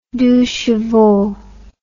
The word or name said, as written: deux chevaux